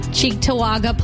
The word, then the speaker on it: Cheektowaga: Cheektowaga Police Department.